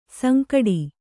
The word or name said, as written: ಸಂಕಟು